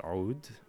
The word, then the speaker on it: oud: So, this is an Egyptian and Middle Eastern instrument called the oud, pronounced oud, spelled O-U-D.